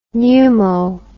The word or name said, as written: pneumo